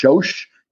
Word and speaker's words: Sós: It's pronounced Sós in Hungary, in Hungarian, but it means of salt or of the ground or of salt.